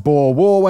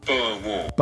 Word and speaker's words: Boer War: It kicked off the First Boer War when the British then annexed.